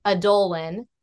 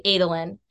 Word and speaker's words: Adolin: I remember my husband and I were arguing over how to pronounce this name in The Way of Kings, and he was pronouncing it Adolin and I was pronouncing it Adolin, and it's actually Adolin. So I feel like I was more correct because I had like the right rhythm, if that makes sense. We both were pronouncing the A softly and it's supposed to be pronounced long.